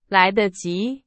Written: 来得及

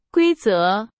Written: 规则

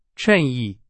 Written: 衬衣

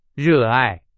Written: 热爱

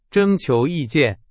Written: 征求

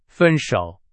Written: 分手

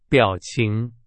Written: 表情

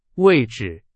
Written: 位置

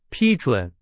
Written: 批准

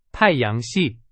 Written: 系

